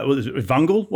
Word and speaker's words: Vungle: And, and, and were you in the UK um, with, uh, Vungle? Was it? Was that? Am I pronouncing that correctly? Before moving to the US? – Yeah, that's right.